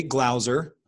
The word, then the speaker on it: Glauser: It's Jay Glauser.